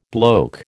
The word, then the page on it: bloke: American English